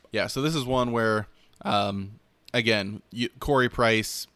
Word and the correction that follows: Carey Price: Carey Price.